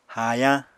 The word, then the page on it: haayañ: Apatani